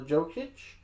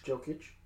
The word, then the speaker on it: Jokic: They have one of, if not the most talented big man in Nikola Jokic. Sorry. – Jokic.